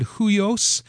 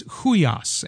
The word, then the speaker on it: huios: My friend, the Hebrew Greek Bible geek, my Bible study mentor, corrected my pronunciation of huios. Huios is the word. I said huios. It's huios, and that is the word that refers to a son who or offspring that has the characteristics of the father.